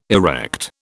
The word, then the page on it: erect: British English